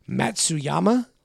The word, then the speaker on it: Matsuyama: I'm sorry if I butcher this name because I'm definitely going to, but it's Matsuyama.